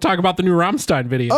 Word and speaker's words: Rammstein: You're a huge Rammstein fan.